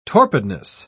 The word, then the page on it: torpidness: British English